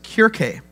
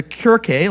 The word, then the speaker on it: Kirche: You see, the term church is a derivative of a German term, and I'm going to probably pronounce it a little off, but it's Kirche.